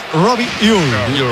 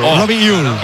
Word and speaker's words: Robbie Ure: A ver, el club pasó incluso un audio, lo ha pasado, y en concreto sería Robbie Uy. Robbie Uy.